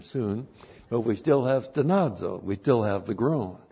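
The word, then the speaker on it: stenazo (στενάζω): Even we ourselves, and then the verb stenazo. S-T-E-N-A-Z, long O, stenazo.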